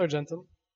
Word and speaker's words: Argentum: And it's pronounced Argentum. That's what it is. It's a Latin word. It means silver.